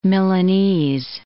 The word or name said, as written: Milanese